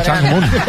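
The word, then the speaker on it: Chang Moon (창문): Chang Moon. – ¿Qué, perdón? – Ventana en coreano se dice Chang Moon.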